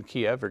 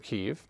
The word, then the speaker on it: Kyiv: I don't know if you've noticed on the news, the pronunciation and the spelling of Kiev has now changed to Kyiv, K-Y-I-V. And the reason is Kiev is the Russian pronunciation and Kyiv is the Ukrainian.